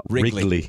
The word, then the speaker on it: Wrigley: It's two syllables, bro. – I know. – Wrigley.